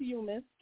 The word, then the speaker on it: Yeomas: Well, my last name is Yeomas. Like, you missed the bus, you missed the ball.